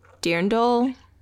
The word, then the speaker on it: dirndl: Yeah, I'm going to say it wrong. It's like a dirndl. – Okay. – Something like that. D-I-R-N-D-L. It's a German word or a Bavarian word.